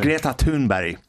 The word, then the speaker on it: Greta Thunberg: Sorry, would you just pronounce her name again there? – Greta Thunberg. – Okay. – And I'll tell you a funny story about Greta. Is that the right way of pronouncing it? – That's the right way of pronouncing her name, yeah.